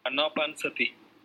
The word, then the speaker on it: anapanasati: Anapanasati.